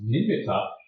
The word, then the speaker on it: nimitta: Usually it's pronounced nimitta, but really the proper pronunciation, nimitta is really the proper pronunciation.